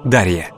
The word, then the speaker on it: Дарья: В современном русском языке имя Дария обычно произносится как Дарья.